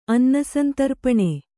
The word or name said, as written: ಅನ್ನಸಂತರ್ಪಣೆ